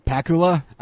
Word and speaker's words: Pakula: Pakula? – I say Pakula because it's like Dracula.